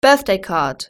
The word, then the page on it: birthday card: British English